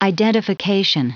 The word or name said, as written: identification